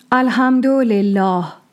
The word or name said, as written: Al-Hamd-u Lillӓh